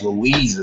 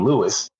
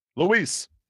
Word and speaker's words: Luis: His name is Louise as well. You called him Lewis. – That's okay. – Louise.